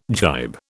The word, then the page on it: gybe: British English